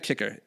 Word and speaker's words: Kiecker: Dana Kicker. I think it's. D-A-N-A, I think it's spelled K-I-E-C-K-E-R. Dana Kicker.